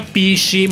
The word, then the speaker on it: PSSI: Ho fondato un partito, il partito Super Sovranista Italiano, PSSI, si pronuncia PC,